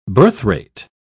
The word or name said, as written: birthrate